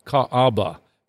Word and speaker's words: Kaaba: And in that part of the world was a shrine called the Kaaba, K-A-A-B-A, sometimes pronounced or spelled K-A-B-A.